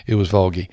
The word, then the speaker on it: Volga: It's actually spelled V-O-L-G-A.